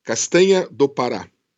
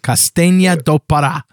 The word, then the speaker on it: Castanha do Pará: Castanha do Pará. – Castanha. – Castanha. – Castanha de Pará. – Do Pará. – Do Pará. – Yeah, that's it. – Castanha do Pará.